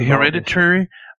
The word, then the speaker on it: Hereditary: Und nee, bei Hereditary, äh, wie wird das ausgesprochen? Meine Fresse. Ja, ja, war richtig.